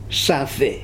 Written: safe

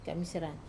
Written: kamisera

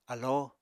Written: alóo

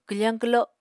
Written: kwfañ kwlo

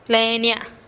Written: le'nia

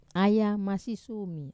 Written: aya masisumi

